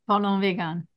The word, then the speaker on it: Parlons: Parlez peu, parlez bien, parlez vegan. – Parlez un peu, parlez bien. Parlons vegan.